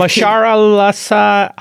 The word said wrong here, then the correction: Mahershala: Ali. God, I'm butchering his Mahershala. – Name. – Mahershala.